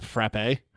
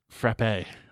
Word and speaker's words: frappe: She also can't pronounce any Maine towns, and she pronounced frap frappe. – What's a frap? Go back to the Delta Quadrant. – Frappe. Like, no, we don't have frappes. We have fraps.